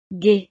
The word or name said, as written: gai